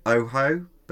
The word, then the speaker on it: OOHO: And they called the packaging, and again, it's pronounced Oho, but it's spelled O-O-H-O, mm-hmm. – which is Oho.